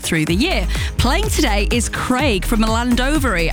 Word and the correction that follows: Llandovery: Have I pronounced that right, Craig? – Nearly, yeah. It's Llandovery. If I was to say it to, like, English people, then it's Llandovery. Drop the first L.